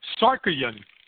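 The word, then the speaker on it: Tsarukyan: As I have chips in my mouth, Sakharyan. – Really? We don't pronounce the T.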